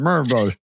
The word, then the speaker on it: Murfreesboro: You just say it with a Southern accent. Murfreesboro. We're going to Murfreesboro.